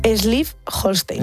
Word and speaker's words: Schleswig-Holstein: a ver si lo digo bien, Schleswig-Holstein. – Yo creo que te ha sonado, vamos, que ha sonado muy bien, ha quedado muy bien.